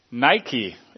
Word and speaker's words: Nike: Nike is actually how it's pronounced, I believe. It actually means victory.